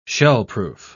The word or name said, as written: shellproof